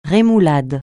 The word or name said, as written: rémoulade